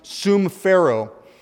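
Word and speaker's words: sumphero (συμφέρω): There's a Greek word there for expedient, and this Greek word for expedient is actually pronounced sumphero.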